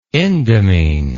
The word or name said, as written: indamine